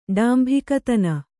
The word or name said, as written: ಡಾಂಭಿಕತನ